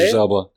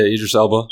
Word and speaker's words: Idris Elba: Idris Elba? – Right. – Is that the way you say it? – Idris Elba. – Idris Elba? – Yeah, Idris Elba.